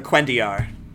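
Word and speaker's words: cuendillar: Yeah, the Kalendor. Or not Kalendor. The Quendiar. – Quendiar, thank you.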